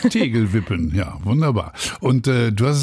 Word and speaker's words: Tegelwippen: Das Ganze ist sozusagen ein Vorbild gibt es in den Niederlanden, das heißt Dorterewippen. Ich hoffe, ich habe es jetzt richtig ausgesprochen. Mhm. Dort wird tatsächlich schon seit 2021 entsiegelt. – Tegelwippen.